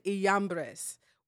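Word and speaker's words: Jambres: And I was pronouncing it Janice. – Janice and Jumbrace. I'm sorry. It's actually pronounced Janice and Iambres.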